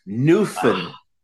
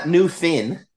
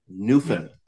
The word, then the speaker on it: Newfoundland: Almost. Newfoundland. So not Newfin. Newfoundland. Yeah. Goofin'. Drinking. – Yes. – Newfoundland. – It's an awesome podcast, man. – Yeah, our provincial anthem is the Ode to Newfoundland.